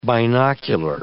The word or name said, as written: binocular